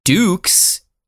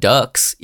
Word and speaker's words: Dux: Yeah, it looks like our boy Frank Dukes, which makes sense, but Dukes is how it's pronounced, not Ducks, even though I've been saying Ducks, because it's spelled D-U-X, Ducks.